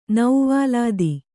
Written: ನೌವಾಲಾದಿ

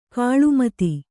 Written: ಗಿಳಿಮಾತು

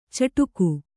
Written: ಚಿಟಿಕೆ

